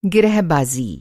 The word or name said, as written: गिरहबाज़ी